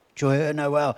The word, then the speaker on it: Joyeux Noël: Joyeux Noël? Joyeux Noël.